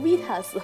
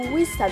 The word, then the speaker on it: Vista: 哎妈，这这期太考验英文了，差点念成 Vitas。啊！在最早微软有出 Vista 的时候啊，很多人就说 Vista 它是一款高仿苹果的系统。